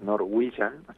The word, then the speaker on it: Norwegian: La pronunciación es Norwegian, medio complicado, pero de a poco vamos a ir acostumbrándonos.